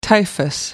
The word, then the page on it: typhus: American English